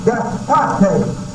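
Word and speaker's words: despotes: And the Greek word for master in 2 Peter 2, 1 is the Greek word despote. As your capital, D-E-S-P-O-T-E-S. I believe that's it. D-E-S-P-O-T, long E-S. Despote.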